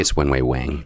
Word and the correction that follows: Wang: It's actually Wong. – Oh, okay. – We've been doing it wrong for years. It's spelled Wang, but it's pronounced Wong.